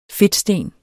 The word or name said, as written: fedtsten